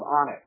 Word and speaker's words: Onx: called Onyx, uh, which is spelled O N X, so it's mm-hmm.